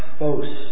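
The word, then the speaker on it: phos (φῶς): And this word light in the Greek is pronounced phos, and it means to shine or make manifest, to appear or affirm or show or make one's thoughts known.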